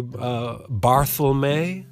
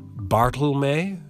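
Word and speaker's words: Barthelme: So, you know, some people say Bartholme, and some say Bartholme. You hear the difference? Barth with a T-H, or just a straight-up Bart, Bartholme, or even Barth Elm.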